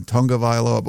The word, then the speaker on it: Tagovailoa: They changed the pronunciation of his name. It used to be Tagovailoa, and now they're saying Tungavailoa, but whatever.